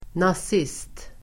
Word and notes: nazist: nas'is:t

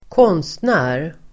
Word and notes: konstnär: [²k'ån:stnä:r]

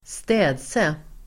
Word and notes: städse: [²st'ä:dse]

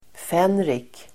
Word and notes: fänrik: f'en:rik

